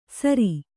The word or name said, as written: ಸರಿ